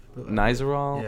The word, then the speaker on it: Nizoral: Is that how you say it? I think so. Or Nizoral. – Nizoral. Yeah.